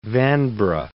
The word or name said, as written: Vanbrugh